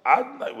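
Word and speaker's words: Adonai: But now we call it Adnai. We're not calling it by Yud Kei Vav Kei. We're not pronouncing it. We're pronouncing as if it would be written with an Aleph, Dalet, Nun, Yud.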